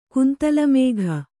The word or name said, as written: ಕುಂತಲಮೇಘ